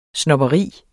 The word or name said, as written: snobberi